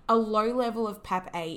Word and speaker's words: PAPP-A: So PAP-A, and that's spelled PAPP-A, mm-hmm.